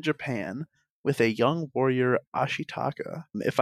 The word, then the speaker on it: Ashitaka: I think that was pretty good, Ashitaka. Okay. – Yeah, that was more just my warning for the rest of the episode. So, the young warrior, Ashitaka, is cursed by a demonized boar god, pushing him to seek a cure in the Western lands.